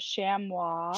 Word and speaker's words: chamois: I think you pronounce it as chamois. – Chamois.